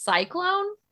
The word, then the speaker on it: C-Clown: I, I, only saw this on one page, but it's supposed to be pronounced cyclone.